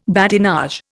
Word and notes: badinage: Indian

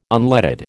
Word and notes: unleaded: American English

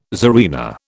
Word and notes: tsarina: British English

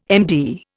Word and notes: MD: Indian

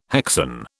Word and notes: hexane: British English

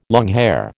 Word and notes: longhair: American English

